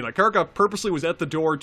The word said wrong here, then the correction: Karkaroff: I'm pronouncing it right. Karkaroff.